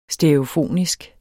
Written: stereofonisk

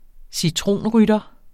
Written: citronrytter

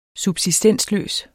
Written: subsistensløs

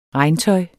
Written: regntøj

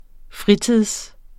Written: fritid

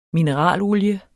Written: MINERALOLIE

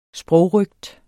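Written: sprogrøgt

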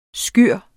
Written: SKYREN

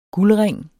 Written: guldring

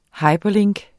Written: hyperlink

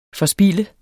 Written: forspilde

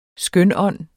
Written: skønånd

